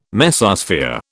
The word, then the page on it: Mesosphere: British English